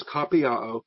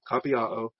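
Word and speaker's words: kopiaō: The verb is kapiao, K-O-P-I-A-O. It's the long O, the omega O there, kapiao.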